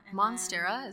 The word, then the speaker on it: Monstera: Monstera, is that how you say it? I always say like Montserrat or something. That's a font.